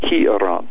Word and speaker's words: hieron: But the Greek word there is naos, N-A-O-S. And there's another word used for temple in the Greek. It's called hieron, H-I-E-R-O-N, hieron. Is how it's said in the Greek.